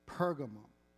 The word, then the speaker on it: Pergamum: I'm going to show you a map from my Bible software, and it's pronounced Pergamum. Pergamum, Pergamos, they're the same thing.